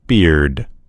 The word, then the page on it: beard: American English